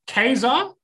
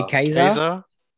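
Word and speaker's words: Ka-Zar: Do you guys, is it Kazar or Kazar? – I say Kazar. – There's a hyphen in the middle. – So you're the only one that's saying it wrong. – Unless you want to say it really emphatically, like Kazar. – Kazar? – Well, in X-Men cartoon, they said Kazar.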